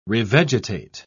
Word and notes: revegetate: British English

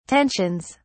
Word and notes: tensions: British English

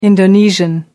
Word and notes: Indonesian: American English

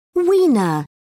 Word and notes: wiener: British English

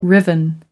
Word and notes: riven: American English

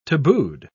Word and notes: tabooed: British English

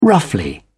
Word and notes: roughly: British English

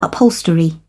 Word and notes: upholstery: British English

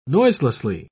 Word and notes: noiselessly: British English

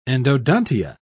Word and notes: endodontia: British English

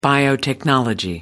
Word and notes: biotechnology: American English

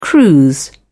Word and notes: cruise: British English

